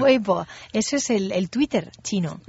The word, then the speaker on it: Weibo: Weibo. – Weibo.